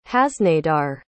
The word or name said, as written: haznadar